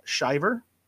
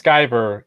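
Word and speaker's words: Sciver: Is it Shiver or Shiver? Or am I pronouncing his last name correctly? – It's Skyver, Ethan Skyver. Van Skyver. – There we go, Ethan Van Skyver.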